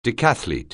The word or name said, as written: decathlete